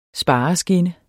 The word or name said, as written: spareskinne